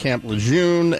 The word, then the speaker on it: Camp Lejeune: For the record, We know it's pronounced Camp Lejeune and that it's Marine Corps.